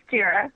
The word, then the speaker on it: Jira: It is Jira. Oh. It comes from the Japanese word for Godzilla, in case you're wondering. – Oh, wow. – It's very appropriate. Jira has been around for a very long time.